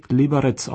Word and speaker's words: Liberec: Genauso wird die nordböhmische Stadt Liberec, zu Deutsch Reichenberg, auf Tschechisch wie Liberec ausgesprochen und nicht wie Liberec oder gar Liberec.